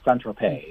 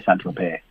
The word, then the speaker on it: Saint-Tropez: It is not pronounced Saint-Tropez. Yeah, yeah. – Saint-Tropez, Some people say Saint-Tropez, but I think the French say Saint-Tropez, yeah.